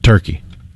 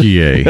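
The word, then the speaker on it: Türkiye: Turkiye okay. Is the pronunciation the country of Turkey has asked the world to give them.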